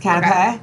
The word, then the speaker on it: canapé: Canapes. – Is that how you pronounce it? – I think it is. Canapé.